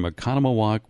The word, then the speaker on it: Oconomowoc: So Jack is on the phone this morning from Oconomowoc, Wisconsin. How are you doing, Jack? – I'm doing wonderful, and you pronounced Oconomowoc well. That's good for a newbie.